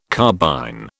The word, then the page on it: carbine: British English